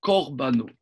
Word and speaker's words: Korbano: J'écrive à Makrive, Carbano, Korbano. – Hachem, Mintra. – Ça se prononce le Kamas, le premier se prononce au Korbano.